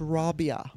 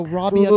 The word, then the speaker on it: Rabia: So we're going to be talking to, and I found out the correct pronunciation is Rabia. So Rabia Chaudhry.